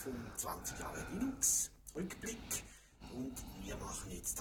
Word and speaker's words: Linux: Den Soundtrenner, den ihr in dieser Episode hört, das ist Linus Torvalds, der vor Jahren einen Namensstreit mal dadurch beigelegt hat, dass er ausgesprochen hat, wie man Linux spricht, nämlich Linux. Ich habe einige Kollegen, die sprechen Linux, Linux und das ist leider falsch.